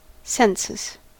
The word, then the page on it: census: American English